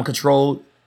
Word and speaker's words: Ctrl: We're going to talk about SZA's album, Control. Pronounce C when I pronounce spells CTRL. – Like a control key on your computer.